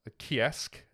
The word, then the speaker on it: Ceasg: In Scotland, the Kiasg, as it's pronounced, but spelled C-E-A-S-G, which again, we found from the Banshee episode, that you don't say it how it's spelled.